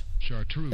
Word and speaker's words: Chartreuse: Chartreuse.